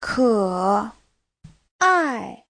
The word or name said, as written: ke3ai4